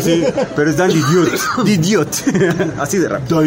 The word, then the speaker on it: DiDio: porque no es Dan Didio. – Es Dan Didio. – Exactamente. Es la pronunciación de Didio. Es como Art Tibet. Es Dan Didio. – Didio. – Así se pronuncia.